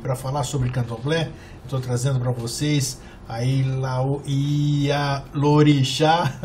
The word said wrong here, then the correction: Ialorixá: o nome é complicado, né? Ialorixá, é.